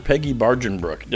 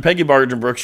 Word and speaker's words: Peggy Barjenbruch: I'm your host, and joining me this week, we have Dr. Peggy Barjenbruch. Did I say your name correctly? – Yes, you did. – Thank you. It's Dr. Peggy Barjenbruch.